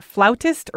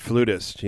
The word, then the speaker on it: flutist: So I was asking if it's pronounced flautist or flutist, or like what the correct terminology is. – I pronounce it flutist, you know, I mean, or principal flute player, or, you know, I'm not really into highfalutin terms.